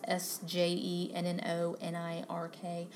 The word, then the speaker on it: Sjennonirk: I think it's how it's pronounced S J E N N O N I R K. I believe it's pronounced Sjennonirk.